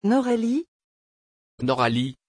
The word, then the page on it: Noralie: French